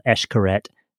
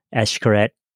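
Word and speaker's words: Xcaret: This was called Eshkaret Sailing, with an X, Eshkaret Sailing.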